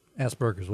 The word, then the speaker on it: Asperger: I've been saying Asperger, but it's Asperger. – Asperger. Asperger. – Well, this is also in Australia, so they have an accent. But I'm sure it's Asperger. Asperger. – I think it's just Asperger's, but you try to not lean on the ass.